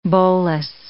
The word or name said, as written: bolas